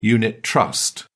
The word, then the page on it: unit trust: British English